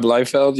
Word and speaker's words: Liefeld: Like, it's Rob Blofeld? How do you say his name? – Oh, Rob Liefeld? – Yeah.